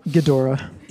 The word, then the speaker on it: Ghidorah: Ghidorah.